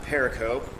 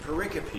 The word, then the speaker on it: pericope: It's not pericope or even periscope. It's pericope, is how you pronounce the term.